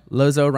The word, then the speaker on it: Lozeau: Lozeau, rhymes with bozo. – Lozeau rhymes with bozo. – But it's not spelled that way.